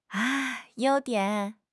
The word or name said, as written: you1 dian3